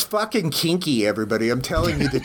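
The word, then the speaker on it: quinqui: Kinqui? – No, I think you're spelling that, it's Kinky. – Kinky? – Kinky. – Kinky. I was saying Kinke, but then I saw a corrected pronunciation of Kinkies. – Okay, well, it's Q-U-I-N-Q-U-I, and it's Spanish, so what's the right way to say it? – Kinky.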